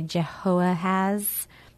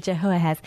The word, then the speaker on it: Jehoahaz: And so the next king after Josiah, let's see, Jehoahaz. Did I pronounce that okay? – You did great. – Jehoahaz.